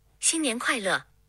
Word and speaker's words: 新年快乐: In Mandarin Chinese, Happy New Year is 新年快乐.